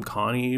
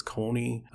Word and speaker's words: Connie: And then another one I pronounce wrong, according to the anime, is I call him Connie, but apparently it's Coney.